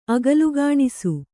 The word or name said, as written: ಅಗಲುಗಾಣಿಸು